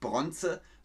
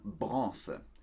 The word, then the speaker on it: Bronze: Man sagt nicht Bronze, sondern Bronze. Die Bronzemedaille.